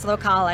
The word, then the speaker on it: locale: So, so, it's actually pronounced locale, eh, which a lot of people mess up, but it, it means local in Italian.